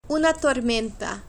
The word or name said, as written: una tormenta